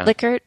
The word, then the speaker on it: Likert: I don't know how to pronounce it, the Likert, I guess. – Likert, yeah.